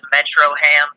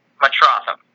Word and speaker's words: Metrotham: Kind of hard to pronounce. A lot of people call it Metroham, but easy way to remember it, mixed Metropolis and Gotham, mush them together, Metrotham.